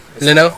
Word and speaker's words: Leno: Oh, I'm not sure how you pronounce his last name, actually. – Lino. – It is Lino? – Lino.